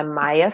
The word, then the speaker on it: Myhia: My book is available on Amazon, and it's called When We Sleep by Maya Francis. My name is spelled M-Y-H-I-A Francis, F-R-A-N-C-I.